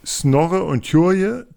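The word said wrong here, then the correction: Tjure: Spricht man ihn so aus? – Snorre ist ja dieser Kleine, der den Helm über den Augen trägt. Ja, an den kann ich mich auch gut erinnern. Den fand ich auch immer sehr lustig. Der war so ein bisschen vorlaut und hat sich immer mit einem gekabbelt. – Ich würde ihn Ture aussprechen, genau.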